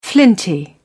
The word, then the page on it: flinty: British English